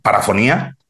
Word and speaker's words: parafonía: una segunda parafonía, Sí. – lo he dicho bien. – Sí.